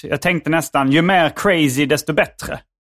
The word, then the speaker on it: crazy: Ja, jag eh, gillar att uttala eh, Z med tonande S. Yes. Yes. Jag är med. Yes. Och eh, ja, jag har hört dig nämna lite eh, om crazy humor i olika sammanhang.